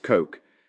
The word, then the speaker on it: Koch: Alex Koch, pronounced Coke, has taken the art of the swipe and sink to new heights.